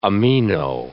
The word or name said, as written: amino